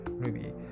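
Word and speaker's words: RWBY: R2WBY と書いて Ruby とか、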